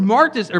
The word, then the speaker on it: martus: And what I find out in this is that the word martus, or martus actually is how you say it,